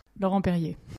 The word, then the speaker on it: Laurent Perrier: champagne maker called Laurent Pierre. – Laurent Perrier that one.